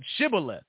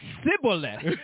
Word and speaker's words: Shibboleth: Then said they unto him, say now, Shibboleth. And he said, Shibboleth. – And no, and he said, Sibboleth. He didn't say the H.